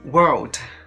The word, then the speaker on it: world: World 就它这个世界这个单词的话，你在发音的时候是不是这个舌头是不是会往后，就是往后伸啊？World 啊，Word W O R D 它的话舌头是不往后伸的，是不是？